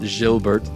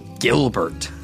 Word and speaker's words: Gilbert: It's not Gilbert like he's French, it's Gilbert.